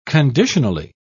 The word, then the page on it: conditionally: British English